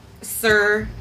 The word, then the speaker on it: Sur: How do you say it? – Sir, S-U-R. – S-U-R? – Uh-huh. – Sir. – Roll those R's. – Sir. – Sir. Can't roll my R's.